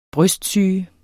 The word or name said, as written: brystsyge